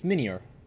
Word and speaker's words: Minior: So, we got Minior... I was trying to figure out how to pronounce this guy. – It's like Meteor, but Mini. It's Minior.